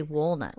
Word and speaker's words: walnut: You say walnut. Walnut. Like you're saying wool, like walnut. from a sheep.